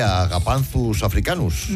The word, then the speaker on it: Agapanthus africanus: Porque es mucho mejor llamar Josefina o Pepita una planta que Agapanthus africanus.